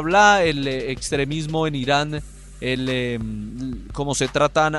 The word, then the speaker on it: Irán: Incluso hoy en la rueda de prensa, Iran eh, fue la, pronunciación de, mm, del capitán de los Estados Unidos. Eh, un periodista le dijo que no, que no se pronunciaba así, que era Irán, no Iran.